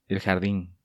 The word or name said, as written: El Jardin